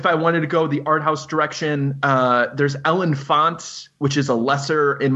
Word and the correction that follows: L'Enfant: I believe movie it's pronounced L'Enfant.